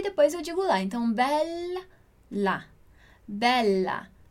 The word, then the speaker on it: bella: Então, eu digo bella em português e tudo bem, em italiano é bella, bella. La vita è bella, la vita è bella. Dillo chiaro? Bella, bella. A gente precisa demorar, alongar essa pronúncia do LA, ok?